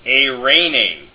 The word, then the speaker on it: eirene: The next diphthong is epsilon iota, pronounced A, as in eirene, which means peace in the Koine Greek scriptures.